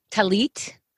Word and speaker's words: tallit: Tallit.